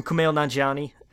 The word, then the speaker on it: Kumail Nanjiani: Oh, from Kumail Nanjiani and Emily V.